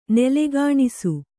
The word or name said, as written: ನೂಲೆಗೆಣಸು